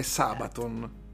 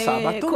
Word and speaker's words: Sabaton: che dubito che l'accento sia sull'ultima sillaba. – No, non è sull'ultima ecco. – sillaba, ecco. è sulla prima, è Sabaton.